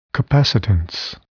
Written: capacitance

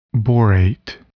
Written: borate

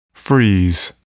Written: freeze

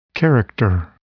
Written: character

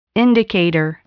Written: indicator

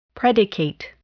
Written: predicate

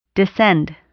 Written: descend

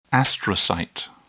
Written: astrocyte

